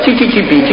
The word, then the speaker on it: СССР: И радостно воскликнул: «О, чи-чи-чи-пи, чи-чи-чи-пи!» Зачирикал так: «Чи-чи-чи-пи, чи-чи-чи-пи!» Оказалось, по-итальянски «СССР» читается как «чи-чи-чи-пи». Вот, мы все с вами из «чи-чи-чи-пи».